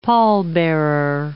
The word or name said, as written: pallbearer